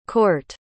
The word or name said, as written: cort